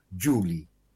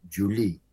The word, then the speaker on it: Julie: Este nombre propio debía escribirse Julie, diminutivo de Julia, y el acento fonético debió caer en la primera sílaba, Julie, y no en la segunda, como aparece en la canción, Julie.